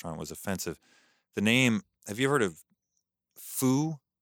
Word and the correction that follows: pho: It's, or no, Pho? It's P-H-O? – Mm-hmm. – How do you pronounce that? – Pho.